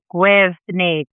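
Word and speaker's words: Gwyrddni: That's spelled G-W-Y-R-D-D, capital N-I. Gwyrni. – You're putting an extra syllable in that. It's Gwyrni. – The double D in Welsh, just pronounced the as in there or then.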